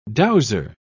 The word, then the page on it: dowser: British English